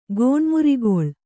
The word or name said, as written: ಗೋಣ್ಮುರಿಗೊಳ್